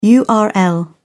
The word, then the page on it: url: British English